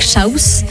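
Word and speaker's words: Shouse: Une nouveauté à mettre dans notre playlist fortement, c'est ce titre Shames en featuring avec Shaus. Mmh. C'est comme ça que ça se prononce. – Shaus, exactly.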